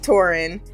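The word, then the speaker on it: Porunn: Is it Torin? I feel like it's spelled Porin, but we pronounce it Torin.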